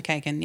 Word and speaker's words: McKagan: McKagan? Is that how you pronounce it? – McKagan, yep.